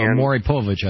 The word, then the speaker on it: Maury Povich: No, Maury Povich, I think, is how it's pronounced.